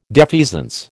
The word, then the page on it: defeasance: American English